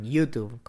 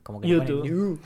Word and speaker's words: YouTube: Viste que en inglés le dicen YouTube. – YouTube. sí, sí, es el acento en la U.